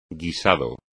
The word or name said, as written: guisado